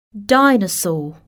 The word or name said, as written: dinosaur